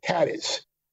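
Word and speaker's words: Cádiz: Like I'm going to go with Cadiz, Cadiz. And as you know, it's not pronounced Cadiz. – Yeah. – That's not pronounced Cadiz. It's Cadiz. The way you pronounce it.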